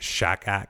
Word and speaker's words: shachad: And they say the bribe is like shakak. That's how you say it. S-H-A-C-H-A-D.